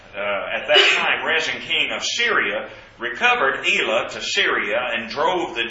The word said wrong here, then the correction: Elath: Jews from Elath and the, I'm sorry, I pronounced that incorrectly, Elath drove the Jews from Elath, and the Syrians came to Elath and dwelt there to this day.